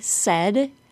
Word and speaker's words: said: Notice that the word said, S-A-I-D, rhymes with bed. Said, bed. Repeat those two words after me. Said, bed. If you pronounce S-A-I-D with a long A, it will sound like you are saying said, S-A-Y-E-D.